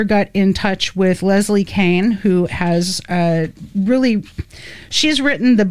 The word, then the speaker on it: Kean: The author writes, Kane, and her name is K-E-A-N, but it's pronounced Kane.